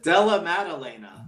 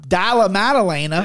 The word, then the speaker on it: Della Maddalena: The one thing I love about Della Maddalena, am I saying that right? Correct me again. What is it? – Della Maddalena. – Della Maddalena.